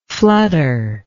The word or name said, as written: flutteringly